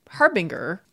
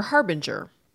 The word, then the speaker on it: harbinger: If you just see this word spelled, there is no reason to think it might not be harbinger. If you think about words like finger or linger that also end with that G-E-R, it could be harbinger or harbinger. And if you listen to pronunciations, you will find every occasionally that harbinger pronunciation.